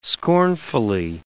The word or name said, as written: scornfully